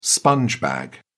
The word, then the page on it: sponge bag: British English